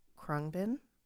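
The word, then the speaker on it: Khruangbin: But they kind of bring this like soothing, calm vibe, almost like Krungbin.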